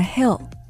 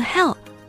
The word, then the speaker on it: hill: 除此之外还有 parking on a hill. – Parking and hap on a hill. – On the hill 啊，就是在山上停车吗？ – 对。发音在这里要注意，山或者是山坡是 hill, H-I-L-L, hill, 如果万一读错了成了 hell, 那是另外一个地方。